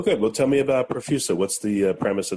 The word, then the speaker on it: Profusa: I have Ben Huang, CEO of Profusa, P-R-O-F-U-S-A. I'm not sure if I pronounced it right, but Ben, is that right? Profusa, the best way to pronounce the company? – You did. That was perfect.